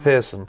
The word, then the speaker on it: Pierson: Spike Pearson.